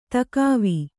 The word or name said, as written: ದಿಖಾವು